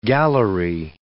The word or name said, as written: gallery